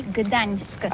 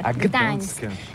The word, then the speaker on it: Gdańsk: Oui, c'est pas très facile pour des étrangers, mais remarque bien que la langue polonaise, c'est la langue où on prononce chaque lettre qu'on écrit. C'est pourquoi on dit Gdańsk. – Gdańsk.